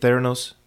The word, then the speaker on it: Theranos: Theranos? – Yes.